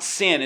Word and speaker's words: syn: we pronounce it sin. It's spelled S-Y-N.